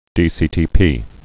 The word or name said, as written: dCTP